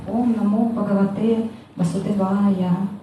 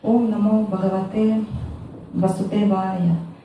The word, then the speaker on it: Ом намо бхагавате васудевая: Когда начинается лекция, мы всегда произносим «Ом намо бхагавате васудевая». Вот Гуру Махарадж произносит так. Когда все преданные остальные произносят, они произносят «Ом намо бхагавате васудевая». То есть они ставят ударение не туда.